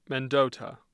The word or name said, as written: mendota